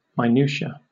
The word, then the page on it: minutia: British English